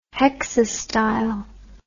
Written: hexastyle

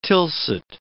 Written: Tilsit

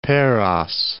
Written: Paros

